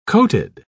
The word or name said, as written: coated